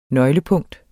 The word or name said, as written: nøglepunkt